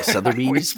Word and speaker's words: Sotheby's: Sotheby's. – Yeah, Sotheby's.